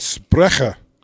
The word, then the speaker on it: Sprecher: That's Sprecher. – Is that really how you say it? – Yeah, that's how you would say it in German.